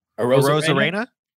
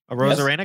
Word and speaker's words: Arozarena: Arozarena. – Arozarena.